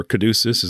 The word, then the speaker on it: Caduceus: Now, I haven't forgotten the Caduceus, or Caduceus, as I sometimes hear it pronounced.